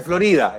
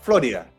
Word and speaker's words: Florida: No se dice Florida, es Florida. Florida.